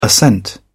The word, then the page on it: ascent: British English